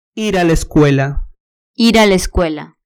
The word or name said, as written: ir a la escuela